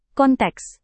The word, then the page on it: konteks: Indonesian